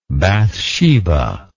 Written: Bathsheba

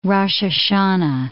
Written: Rosh Hashanah

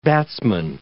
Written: batsman

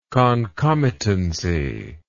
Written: concomitancy

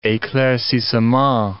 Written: eclaircissement

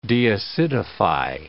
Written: deacidify

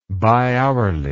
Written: bihourly

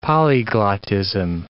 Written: polyglottism

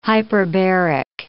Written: hyperbaric

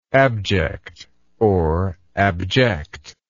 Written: abjectedness

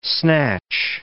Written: snatch

